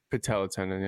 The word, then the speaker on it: patella tendon: Patella tendon, yeah.